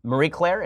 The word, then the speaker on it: Marie Claire: So there's an article that's just astonishingly great in its stupidity from Marie Claire.